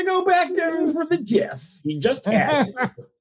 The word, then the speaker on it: GIF: It's a GIF. It's a hard G.